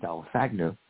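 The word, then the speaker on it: Dalaithngu: But, you know, again, we want to just really celebrate the gifts that David Guilipil Dalasagnu, that's how you pronounce it, Dalasagnu, gave to us.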